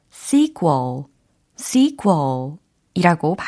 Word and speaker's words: sequel: sequel, sequel 이라고 발음이 됩니다. 철자는 S-E-Q-U E-L입니다. 그래서 sequel 이게 아니라 sequel, sequel 이렇게 발음을 해주셔야 됩니다.